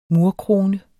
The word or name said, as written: murkrone